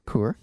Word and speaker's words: Kaur: So I mentioned that we had Dr. Kaur, K-A-U-R, but pronounced Kaur, kind of like the beer.